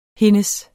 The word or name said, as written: hendes